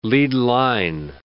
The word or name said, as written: lead line